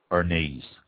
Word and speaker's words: Arneis: Arneis, A-R-N-E-I-S, pronounced Arneis.